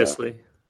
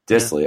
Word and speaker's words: Dissly: Are we are, are we believers in Dissly, or are we questionable? There's Dissly. I don't know how to say his last name. Will so. Dissly. – Dissly. Yeah, okay, there it is.